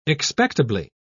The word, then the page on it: expectably: British English